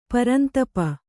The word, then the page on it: ಪರಂತಪ: parantapa